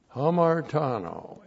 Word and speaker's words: hamartano: Hamartano, H-A-M-A-R-T-A-N, long O, hamartano.